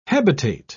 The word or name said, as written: hebetate